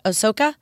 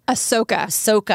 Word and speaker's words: Ahsoka: And he even has a pup named, I don't know how to say this, Ahsoka? – Ahsoka. – Ahsoka, after yes.